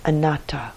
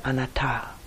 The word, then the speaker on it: anatta: The last of the three characteristics that we may come to know through this fourth domain of mindfulness is anatta in Pali. Sometimes it's pronounced anatta.